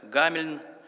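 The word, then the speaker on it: Hameln: Согласно легенде, в лето от Рождества Христова 1284-е в начале июня месяца славный немецкий город Хамельн, иногда читается как Гамельн,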